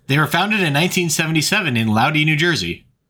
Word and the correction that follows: Lodi: L-O-D-I? I've never heard of it. – It's pronounced Lodi.